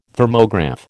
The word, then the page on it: Thermograph: American English